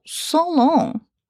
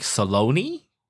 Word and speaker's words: Solone: Oh, there is a brand, the cosmetic brand called Solong. Solong. S-O-L-O-N-E. Solong. – Oh, so I would pronounce it more like Saloni. – Saloni? – But Saloni sounds like salami, so no. Maybe Solong is better. It's a weird brand, S-O-L-O-N-E.